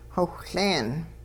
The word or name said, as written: xowhLeen